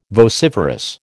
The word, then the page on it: vociferous: American English